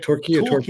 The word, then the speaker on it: Torquay: And is it Torquay? Is that how you say it? Torquay.